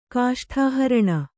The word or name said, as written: ಕಾಷ್ಠಾಹರಣ